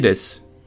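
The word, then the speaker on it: Des (です): Kimura Takeshi des. Was sagt er? Er sagt Konnichiwa Kimura Takeshi des. Also guten Tag, Konnichiwa. Kimura Takeshi des. Des am Schluss. D-E-S-U wird aber ausgesprochen mit dem starken S.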